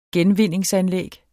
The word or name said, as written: genvinde